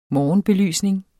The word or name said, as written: morgenbelysning